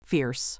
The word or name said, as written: fierce